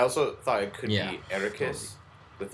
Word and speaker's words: Arrakis: Arrakis. – Arrakis.